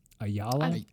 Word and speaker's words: Ayala: A-Y-A-L-A. Ayala? Ayala? – I would say Ayala, because I knew someone whose last name was Ayala. – Wow, okay. – So I'm going to say that is the pronunciation.